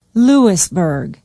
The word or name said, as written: louisburg